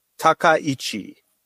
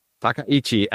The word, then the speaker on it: Takaichi: Takaichi. – Takaichi.